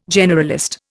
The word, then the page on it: generalist: Indian